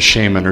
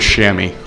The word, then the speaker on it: chamois: And I'm going to go with the pronunciation of chamois on that as opposed to shaman or shaman. Or Shamoon, or Shamu, or whatever y'all want to go with. I'm going to go with Shammy.